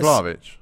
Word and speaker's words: Vlahovic: Oh, Vahalovic. For me, Isak's cold. – You mean Vlahovic? – Yeah, Vahalovic, yeah. – Vlahovic, same thing.